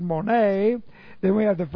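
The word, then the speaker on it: mone (μονή): Now, the Greek word, though, just to edify ourselves in this regard, the Greek word is mone. Looks like this. Mone, M-O-N, long E. Mone, and mone comes from the verb meno, which looks like this in the Greek, M-E-N, long O, meno.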